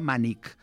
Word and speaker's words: mànec: És a dir, quan una cosa és per agafar, se li diu un mànec, que en alguns llocs es pronuncia mànic, per exemple. Al Camp de Tarragona es pronuncia mànic. Però bé, és una variant d'aquelles que comentàvem abans. – I el mànic, jo també. El... – Sí, sí, a Ponent també mànic, però s'escriu mànec.